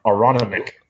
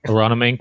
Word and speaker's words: Aronimink: Well, Jesse, apparently that's how we pronounce that golf course that we couldn't figure out 2 weeks ago. Aronimink. – Aronimink.